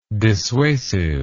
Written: dissuasively

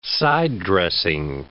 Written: side-dressing

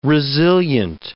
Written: resilient

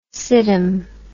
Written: siddham

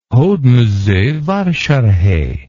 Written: hodmezovasarhely

